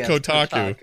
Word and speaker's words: Kotaku: Did I say it wrong? Is it Kotaku? – I think it's Kotaku. Kotaku.